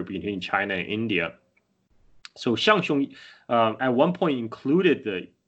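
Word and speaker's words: Zhang Zhung: Shangxiong is spelled Zhang and Zhung in English. It's a romanization of the old Tibetan, but a lot of English speaker probably has no idea how to pronounce it. So it's actually pronounced Shangxiong. In that way, the Chinese transliteration probably, it's much closer to the Tibetan reading.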